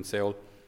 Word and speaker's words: Seoul: one in Seoul, or Seoul as we pronounce it, and one just up at the border near North Korea, where if he crosses, he'll be shot.